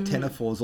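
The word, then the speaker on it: ctenophores: This, I'm pronouncing it weird because it starts with a C. It's got a silent C. So tenophores also has a silent C at the beginning.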